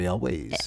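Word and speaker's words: Elwes: How do I say his last name? – Carrie Elways. – Elways, yeah.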